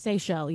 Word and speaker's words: Seychelles: Seychelles, yeah. – The Seychelles, yeah. – The island, islands. – S-E-Y-C-H-E-L-L-E.